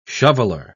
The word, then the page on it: shoveller: British English